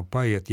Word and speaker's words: поэт: «По» может звучать отчетливо. Видите, может звучать отчетливо. То есть нам отнюдь не запрещено говорить «поэт», то есть подравнивая, подстраивая это слово под общее правило произношения безударного «о», то есть превращение его, по сути дела, в звук почти что совпадающий с «а».